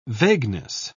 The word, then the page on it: vagueness: British English